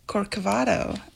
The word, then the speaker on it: Corcovado: Corcovado.